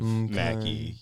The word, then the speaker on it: Mackey: McKay. Emma McKay. – It is, I believe, Mackie.